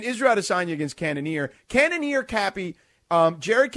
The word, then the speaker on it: Cannonier: Cannonier.